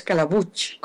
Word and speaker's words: Calabuch: Es muy fácil de pronunciar, es Calabuch, como si tuviera una che al final.